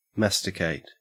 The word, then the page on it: masticate: Australian English